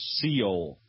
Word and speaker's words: Seoul: I believe it's pronounced Seoul.